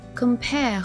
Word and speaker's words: compare: Shall I 连读， compare 和 summer 的结尾都是 R，在英音中不用卷舌，然后 thee 的发音要咬准。